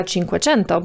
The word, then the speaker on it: Cinquecento: Włosi powiedzieliby Fiata Cinquecento, bo tak właśnie wymawia się po włosku ten liczebnik, ale auto, którym wyruszył Altare, bardziej przypominało współczesnego Fiata 500 niż model znany w Polsce jako Cinquecento.